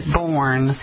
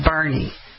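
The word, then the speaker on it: Boerne: They say that outsiders pronounce it Bourne, and the locals say Bernie.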